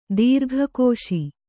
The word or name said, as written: ದೀರ್ಘಕೋಶಿ